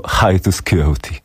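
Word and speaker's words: Hiatus Kaiyote: Z drugiej strony mocno mi przypominają omawiany w poprzedniej audycji Hiatus Coyote, który wymawia się prawidłowo Hiatus Coyote.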